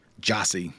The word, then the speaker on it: Gyasi: G-Y-A-S-I, yep. Last line of the Friday poster. And it's pronounced what? – Jossie. Like J-O. It's either J-A-H-S-I or Got J-O-S-S-Y, it.